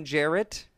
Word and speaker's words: Jarrett: And well, first episode spelled it out, I was like, I am Jarrett, J-A-R-R-E-T-T.